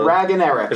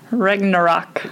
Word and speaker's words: Ragnarok: Ragnarok. – Is that how you pronounce it? – No, not at all. – Ragnarok.